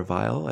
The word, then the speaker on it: Weil: It's written by Barry Mann and Cynthia Weil or Vile. I don't know if she probably used the American pronunciation Weil, who were very famous brill building songwriters.